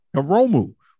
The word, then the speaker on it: Hiromu: What is it? Himuru? Am I saying his name right? Himuru? – Hiromu.